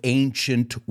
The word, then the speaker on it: ancient: So in the first episode, we're going to focus on the seven wonders of the ancient world. And I want you to pronounce that with me. Ancient. Like the word Asia or agent. It starts with this A that sounds like an A.